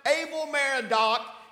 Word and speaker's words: Evil-Merodach: now this is spelled, it looks like evil, but it's pronounced Evil-Merodach, king of Babylon,